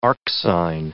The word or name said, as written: arcsine